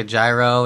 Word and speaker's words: gyro: Is that like a gyro and some like a cereal bowl? What's going on? – I'm pretty sure it's gyro. Did I say that right, Mark? – I believe you did, yes.